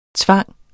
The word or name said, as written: tvinge